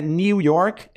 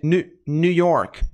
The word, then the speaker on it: New York: Pronúncia não é New York, é New York, New York, New York, New York, New.